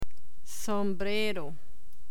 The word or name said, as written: sombrero